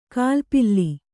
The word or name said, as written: ಗಾಲಿಪಲ್